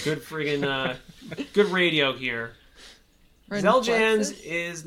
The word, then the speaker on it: Xeljanz: Number 11, Xeljanz. – Xeljanz.